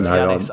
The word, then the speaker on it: Giannis: let me just Google it quickly. Yanis. All right. – I know that's how it's pronounced, yeah, but I'd love to hear both of you UK guys take a shot at it.